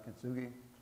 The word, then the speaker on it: kintsugi: You've probably heard of a Japanese art form called kintsugi, I believe it's pronounced kintsugi, and it involves repairing broken pottery.